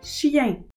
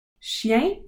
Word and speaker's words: chien: Chien, dog. Chat, cat. souris, mouse, lapin, rabbit, chèvre, goat, mouton, sheep, vache, cow, chien, chat, souris, lapin, chèvre, mouton, vache. Chien is spelled C H I E N.